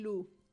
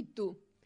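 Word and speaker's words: Lou: Entre cielo e lu. E lu. Porque todo mundo candole di entre cielo e tú. No, é e lu, que é o nome da muller do protagonista.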